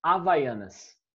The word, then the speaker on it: Havaianas: Havaianas. – No, you're not going to pronounce it like an R. You're going to pronounce it like a silent H. Havaianas.